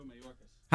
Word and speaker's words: Mayorkas: How you pronounce it? – Alejandro Mayorkas.